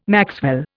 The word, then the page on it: maxwell: Indian